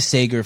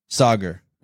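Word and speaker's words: Saagar: so let's go back to Sagar for a second. Will you pronounce his name for me? The guy from Breaking Point. – Sagar. – Sagar. Yes. Sagar.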